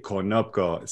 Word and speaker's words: Konopko: See, Konopko.